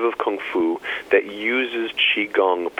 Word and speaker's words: Qigong: Now, you are a Qigong master.